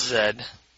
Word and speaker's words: Z: So the first one said, "I'm hearing more and more people pronounce the letter Z as Z." – Yeah. – "And I'm not happy about it. It should be pronounced Zed."